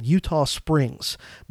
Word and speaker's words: Eutaw Springs: And then the last major battle of the war in the Carolinas was a, a, battle called Eutaw Springs, but it's Eutaw not spelled like the present day state of Utah, it's spelled E U T A W, Springs.